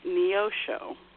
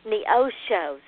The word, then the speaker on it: Neosho: Okay, the city, it is an Indian name, but it's Neosho. – Neosho.